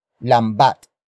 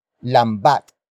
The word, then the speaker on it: lambat: It is the focus marker that introduces the main topic of this sentence, and that is lambat. Lambat. Lambat. Lambat is spelled as L-A-M-B-A-T.